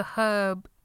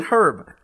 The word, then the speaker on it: herb: Is it that the herb is leaves and a spice? – Wait, so you do pronounce the H in herb? – Yes, I pronounce the H in herb, but not in time.